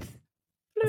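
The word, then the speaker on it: Fflewddur: Fluter. I forgot you just, the first F is silent.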